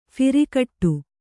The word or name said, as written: ಫಿರಿಕಟ್ಟು